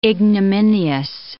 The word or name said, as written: ignominious